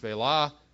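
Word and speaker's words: Shephelah: I guess we won't read those again, but it says that those in the Negev, it talks about those in the Negev, the Shvelah, yes, pronounced Shvelah, in Ephraim, Benjamin, talks about Zarephath, Sepharad, and all these places.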